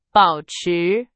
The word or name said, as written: 保持